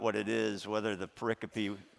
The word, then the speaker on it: pericope: It's called, the word is think from the Latin, pericope, pericope, P-E-R-I-C-O-P-E.